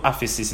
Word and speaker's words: aphesis: It's the word aphesis in the Greek. Aphesis is spelled A-P-H-E-S-I-S.